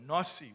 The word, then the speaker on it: Nasi: So the Rebbe says, I'm sorry that I'm not addressing you with that title, but that word, Nasi or Nasi, we would say Nasi, how we would pronounce it.